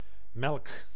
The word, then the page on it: melk: [mɛlk]